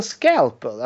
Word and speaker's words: scalpel: Skulpel. – Skulpel? What is a skulpel? You mean, you mean scalpel?